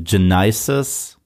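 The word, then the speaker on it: Genisys: Ja, das heißt nächste Woche äh, Salvation, dann Gen Genesis, so müsste man ihn eigentlich aussprechen, weil ein Y drin ist.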